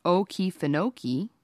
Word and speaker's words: Okefenokee: The Native Americans named the swamp Okefenokee, pronounced Okefenokee, from a native word meaning land of the trembling earth.